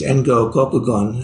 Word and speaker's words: Engo Kokugon: Then Dogen quotes Yuan Wu Keqin, a Chinese master of the, I think the 1100s, who in Japanese, Engo Kokugon, this is how you say his name, he was the commentator of the Blue Cliff Record.